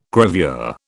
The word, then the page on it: gravure: British English